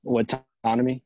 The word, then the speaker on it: Whatoname: Whatoname.